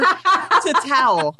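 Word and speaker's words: tatau: Or Tatal, if you want to go with the native pronunciation.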